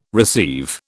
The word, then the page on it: receive: British English